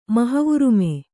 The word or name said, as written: ಮಹವುರುಮೆ